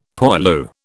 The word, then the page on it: poilu: British English